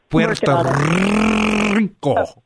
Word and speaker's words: Puerto Rico: You need to roll your R's a little bit more. Puerto Rico.